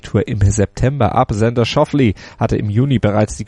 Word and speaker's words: Schauffele: Denn seine Familie stammt aus Schwaben und sein Name würde dort wahrscheinlich deutlich anders ausgesprochen werden, weiß unsere Schwaben-Expertin Desiree Wolf. – Naja, dann wäre der Alexander Schauffele.